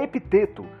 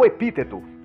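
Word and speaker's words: Epicteto: Logo no início, o tradutor Aldo Dinucci traz a informação de que, como a maioria dos nomes gregos, a pronúncia correta do nome seria epiteto, com o E pronunciado longo. Mas, por efeito de comunidade e adaptação, uso nesta análise a forma oral epíteto, como de costume na maioria das vezes em que esse nome é pronunciado na internet.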